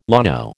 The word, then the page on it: llano: American English